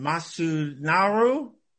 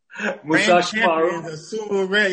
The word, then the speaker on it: Musashimaru: Aki Bono and Masu Naru. – Musashimaru.